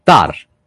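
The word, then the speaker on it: Tár: Poi, Tar, con l'accento sulla A.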